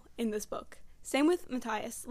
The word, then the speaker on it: Matthias: I have 2 points about Inej and, Matthias, right? – Yeah, Matthias.